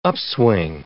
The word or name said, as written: upswing